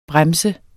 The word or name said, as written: brems